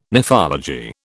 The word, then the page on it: nephology: British English